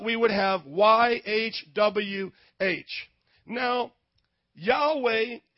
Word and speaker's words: Yahweh: So it's YAHWEH, Yahweh.